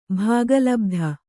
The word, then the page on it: ಭಾಗಲಬ್ಧ: bhāga labdha